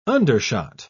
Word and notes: undershot: British English

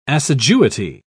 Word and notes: assiduity: British English

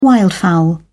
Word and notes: wildfowl: British English

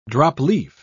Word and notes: drop-leaf: British English

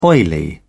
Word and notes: oily: British English

